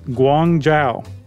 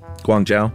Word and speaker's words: Guangzhou: By the way, Josh, you pronounced it super accurately. – This is how you pronounce it. Guangzhou. – Guangzhou. Good job.